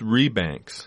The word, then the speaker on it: Rebanks: Say his name again. – Yeah, James Rebanks. – Rebanks. Rebanks. – That's right.